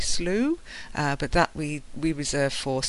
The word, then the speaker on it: Slough: After falling into a slough, he coughed and hiccuped.